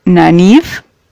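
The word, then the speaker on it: Nynaeve: Perrin y en la serie parece que lo pronuncian como Nanif, pero si viste la serie o, o has leído los libros me entenderás.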